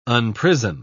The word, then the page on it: unprison: British English